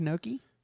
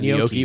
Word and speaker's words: gnocchi: You ever had gnocchi? – It's good. They're good. – How'd you pronounce it? – I've not had gnocchi.